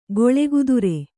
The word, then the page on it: ಗೊಳೆಗುದುರೆ: Kannada